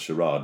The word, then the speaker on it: Charade: And, there was an interview with Stanley Donen once about that, actually, where they said, this is nonsensical pronunciation of Charade. It's pronounced Charade. And he goes, well, I don't say I would like a glass of lemonade.